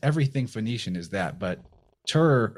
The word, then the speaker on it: Tyr: We say Tyr, T-I-R. It's phonetically looks a lot like the word that's spelled T-Y-R-E, which is a so-called Phoenician word.